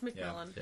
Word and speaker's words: McMillan: How you say it? McMillan? – Yeah. – I think it's McMillan. – It's McMillan.